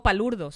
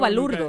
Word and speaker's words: palurdo: Cuando digo palurdo. Nunca digo no, balurdo. Nunca. – ¿Por qué? – Porque se escribe palurdo. – Ajá, pero ¿en dónde? – En la RAE. Okay. En la Real Academia Española. Es palurdo.